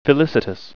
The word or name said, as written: felicitous